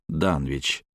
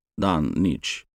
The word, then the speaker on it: Данвич: Неизвестно только, произносил ли автор название на британский манер «Данвич», ближе к «Данич».